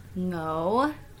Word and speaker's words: Ngo: Yeah, so my name is Annee Ngo. You did a good job pronouncing my last name. no? Yeah. – Okay. It's like bingo, but without the B-I.